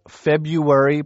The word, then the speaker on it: February: John, I'm just wondering, you know, February, pronounced without the first R, has been mispronounced so often that it's now in the dictionary under the incorrect pronunciation, as are a number of other words.